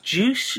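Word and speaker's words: Juche: I mean, my understanding is it's all to do with the is it pronounced juice? – Yes, that's right.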